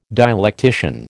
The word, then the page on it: dialectician: American English